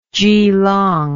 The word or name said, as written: Jilong